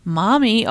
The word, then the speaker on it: Maumee: He comes from Maumee, Ohio.